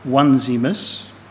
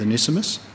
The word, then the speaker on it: Onesimus: Next week, Dan will be talking a bit more on the other of Paul's friends, whose name is Onesimus, although I've heard people pronounce it Onesimus. I'm going down as being correct with Onesimus.